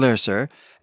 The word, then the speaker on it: Lerser: His name is Egon Lerser, and to be clear, I am pronouncing this like it's Goethe, but to an Anglophone reader, the name looks like loser on the page, and that's intentional.